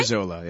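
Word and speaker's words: bresaola: And I'm really enjoying a piece of Brizola. Am I saying it right? – Brizola, yeah. – Brizola. – B-R-E-S-O-S-A-O-L-A.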